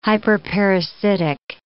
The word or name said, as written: hyperparasitic